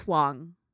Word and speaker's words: Wong: Oh, so I've listened to a few of those, and it just seems like, I mean, Garrett Wong just seems like a sweetheart. – Did I say his name wrong? – Oh yeah, it's Wong.